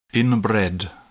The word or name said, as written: inbred